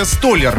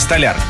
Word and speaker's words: столяр: и «Столяр», да, в профессиональных кругах, а правильно, «Столяр».